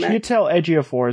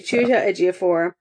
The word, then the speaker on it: Chiwetel Ejiofor: Chiwetel Ejiofor. I can never say his name. – Chiwetel Ejiofor is playing him. – Thank you. Chiwetel Ejiofor.